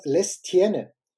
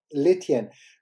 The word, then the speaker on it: Lestienne: Dominik Thiem hat ja in der ersten Runde in München gegen Constant, äh, Constant und den Nachnamen schreibt man Lestienne. Und ich hätte schon Lestien gesagt, aber Alexi hat mir, hat mir die Ohren lang gezogen und hat gesagt, äh, natürlich wird das S nicht ausgesprochen. Constant Letien.